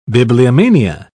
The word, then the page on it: bibliomania: British English